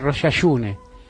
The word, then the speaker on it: Rosh Hashanah: Y decían agitur, y pronunciaban distinto muchas cosas, no decían Rosh Hashanah. Me estoy metiendo en cualquiera, ¿no? Pero digo, no importa, es lunes. Y después vamos a tener dos invitados de lujo y vamos a hablar de cosas muy serias. Pero mis abuelos decían Rosh Hashune, ¿no? Pronunciaban Rosh Hashune.